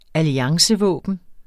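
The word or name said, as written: alliancevåben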